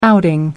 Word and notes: outing: American English

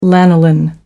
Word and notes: lanolin: American English

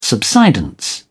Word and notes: subsidence: British English

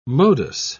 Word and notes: modus: British English

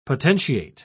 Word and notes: potentiate: British English

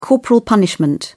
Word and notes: corporal punishment: British English

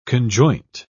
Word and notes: conjoint: British English

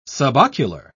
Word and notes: subocular: British English

